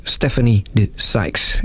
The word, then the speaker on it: Stephanie de Sykes: Haar naam is Stephanie de Sykes. Is that a good pronunciation? – Very good, excellent.